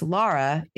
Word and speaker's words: Laura: It's not it's not Laura. It's Laura. It's spelled differently.